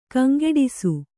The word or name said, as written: ಕಂಗಿಡಿಸು